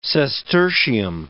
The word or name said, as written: sestertium